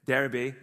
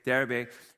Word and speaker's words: Derbe: Derbe is apparently how you're supposed to say it, okay? Derbe.